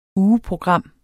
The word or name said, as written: ugeprogram